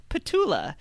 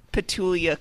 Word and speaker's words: Petula: Colian says, the question of the day, will Andrew learn how to correctly pronounce Petula? He adds an I in there for some reason.